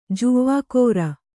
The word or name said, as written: ಜವಕಾರ